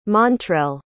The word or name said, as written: montrel